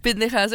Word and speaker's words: pendejada: ¿Por qué preguntas puda perrejada? – pendejada se pronuncia normal porque no hay una R, güey.